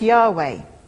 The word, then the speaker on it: Yahweh: But what it is important to realise is the significance of the word translated here as Lord, which would originally have been written down in our script as YHWH, which we generally pronounce Yahweh.